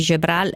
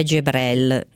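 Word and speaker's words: Jebreal: che innanzitutto ci dice che la pronuncia di Gebral è Gebrel,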